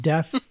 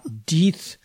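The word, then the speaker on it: Deth: Yes, Bill, it's pronounced Death, not Deeth.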